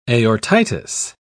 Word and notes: aortitis: British English

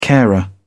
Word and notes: carer: British English

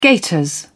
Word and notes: gaiters: British English

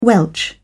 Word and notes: welch: British English

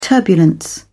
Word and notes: turbulence: British English

